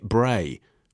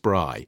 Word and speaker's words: braai: butchered the South African barbecue word braai by calling it bray. Please know it is pronounced braai.